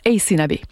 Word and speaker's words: Aysanabi: Et là, vous êtes nombreux à nous avoir écrit pour nous demander qui était donc Ace Nabi, comment on fait pour retrouver sa musique. Alors, je vous épelle son nom, c'est AYSANABI. Ça se prononce Ace Nabi.